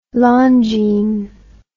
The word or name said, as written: Longines